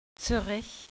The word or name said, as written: zuerich